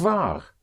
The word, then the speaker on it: wahr: War also means true. And that is spelled W-A-H-R, but it has the same sound, same pronunciation as was.